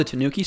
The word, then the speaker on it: Tanuki: The one with the Banuki suit? Is that, what is it? – It's actually spelled with a T, Ryan. It's called the Tanuki suit.